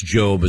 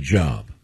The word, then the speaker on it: Job: Now, because that is pronounced Job as opposed to Job.